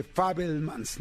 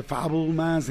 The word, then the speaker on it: Fabelmans: Y, el otro estaba en una cena y dicen, no, pues tiene que ser más bien como, como él es judío, de Fabelmans, ¿no? De Fabelmans, entonces, porque eh, eh, pues más bien la comunidad judía lo dice así, así es como se pronuncia, ¿no?